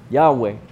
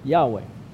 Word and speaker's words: Yahweh: But the God of the Old Testament has a name, Yahweh. Yahweh is perhaps the closest guess for how to pronounce something in Hebrew that is a series of consonants.